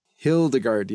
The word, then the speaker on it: Hildegard: Hildegard, yeah.